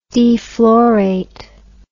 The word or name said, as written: deflorate